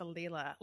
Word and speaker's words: Leela: Well, I was saying it wrong the whole time until they called her Lee. And then I was like, oh. – Yeah. – Because I think I was calling her Layla.